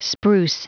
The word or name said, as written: spruce